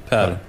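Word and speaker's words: Paire: Mm, hur uttalas hans namn? – Per. Per.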